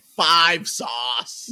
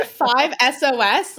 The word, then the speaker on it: 5 Seconds of Summer: The only thing worse than saying 5 seconds of summer is saying Five Sauce. – You don't like five? That's how you say it. You've never heard that? – It makes sense. So punk like, rock. I left crap. – If you say five SOS, like you're gonna catch hands with someone, I swear to God.